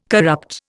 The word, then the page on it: corrupt: Indian